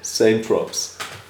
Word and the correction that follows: Saint-Tropez: Heißt das nicht Saint-Tropez? – Oder Saint-Tropez, falsch ausgesprochen.